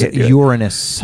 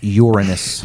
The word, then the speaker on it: Uranus: All right, jokes aside, no, the proper pronunciation is Uranus. – It is not your butt. It is Uranus.